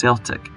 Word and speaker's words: Celtic: The two men always pronounced Celtic as Celtic.